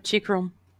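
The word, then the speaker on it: Chychrun: Como é que fala o nome dele? Chikron. Chikron, alguma coisa assim. – É o Chikron.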